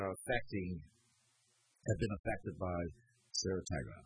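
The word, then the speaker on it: ciguatera: So, I want to say this right. It's Saratoga. That is the poison that these larger fish. Saratoga. Right, so like cigarette, Saratoga.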